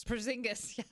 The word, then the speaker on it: Porzingis: And then, I can't ever pronounce his name, Kristaps Porzingis? – Porzingis, yes.